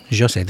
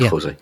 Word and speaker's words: José: José, they pronounce it. – I say José. – In Portuguese. – That's because I'm doing Spanish rather than Spanish. – Exactly. – Portuguese. – I learned this week how to pronounce the name. – Okay. So, and I'm probably still not getting it quite correct. But they do pronounce the J differently. – As a J. Okay. – Yeah. Good. Not quite a J. It's like a J, kind of.